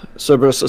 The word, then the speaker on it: Cerberus: Would I have seen the Cerberus? How do you say that? – Cerberus.